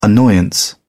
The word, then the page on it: annoyance: British English